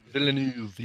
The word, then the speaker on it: Villeneuve: And I think that Denis Villeneuve, how do you say his name? – Villeneuve, yeah.